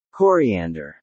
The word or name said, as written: coriander